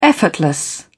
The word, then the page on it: effortless: British English